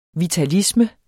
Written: vitalisme

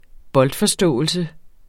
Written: boldforståelse

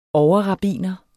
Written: overrabbiner

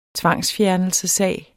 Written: tvangsfjernelsessag

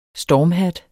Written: stormhat

